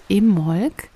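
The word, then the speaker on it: Imbolc: Also das erste Jahreskreisfest im Jahr heißt Im Molk, geschrieben mit B, Im Molk. Aber richtig ausgesprochen, wie ich gelernt habe, als ich in Südengland war letztes Jahr, ohne B, also im Molk.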